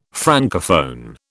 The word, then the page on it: Francophone: British English